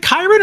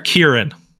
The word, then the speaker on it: Kyren: Is it Kyren or Kieran? – Kyren. – Okay, that's what I thought.